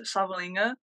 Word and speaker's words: Savalinga: So my name is Savalinga, pronounced with an N, but without an N. That's what I always tell everybody.